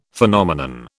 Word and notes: Phenomenon: British English

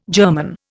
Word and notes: germon: Indian

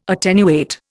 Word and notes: attenuate: Indian

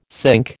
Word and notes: cinque: American English

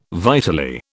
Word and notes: vitally: British English

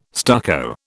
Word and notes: stucco: British English

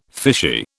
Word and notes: fishy: British English

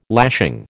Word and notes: lashing: American English

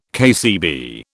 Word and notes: KCB: British English

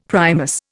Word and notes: Primus: Indian

